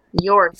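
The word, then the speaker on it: Jörg: Usually the J's are, like, Y's. Jorg.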